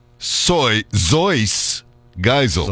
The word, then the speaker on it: Seuss Geisel: But his name is Theodore Seuss Geisel, but that's not how you pronounce it in German. – Ah. – It's a German name. – Yes. – Theodor Zeuss Geisel.